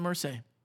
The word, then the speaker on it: Irsay: Jim Irsay.